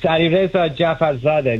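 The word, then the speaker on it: Alireza Jafarzadeh: With us today is Alireza Jafarzada. Alireza, did I say it right? – Perfect. It's Alireza Jafarzada.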